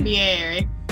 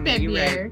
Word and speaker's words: February: You ain't saying it right. – How is it again? – February. Just February. Right.